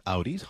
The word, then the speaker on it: Audi: Did I mispronounce two brand names? – You did. – There? – It's a Volvo and not a body part, and it's an Audi.